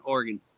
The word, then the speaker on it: Oregon: It's like so, Oregon, basically. – So O-R-G-U-N, Oregon.